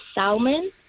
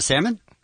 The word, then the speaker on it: salmon: I say salmon. – Oh, instead of salmon? – Yeah. And I mean, there is an L there.